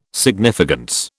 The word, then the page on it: significance: British English